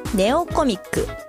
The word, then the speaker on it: 弟コミック: Google プレイストアでネオコミック、弟コミックと書いてネオコミックと読みます。